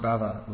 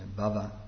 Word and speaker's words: bhava: It's not bhava with a long A, it's bhava with a short A, which means in this case material existence.